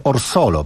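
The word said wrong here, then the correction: Orgosolo: prawda? – Tak, w Orgozolo. – Orgozolo. – Tak, ciężka nazwa. Mnie zajęło kilka dni, żeby nauczyć się wymawiać. Mam nadzieję, że jest poprawnie. Powinno być już poprawnie.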